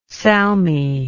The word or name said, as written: salmis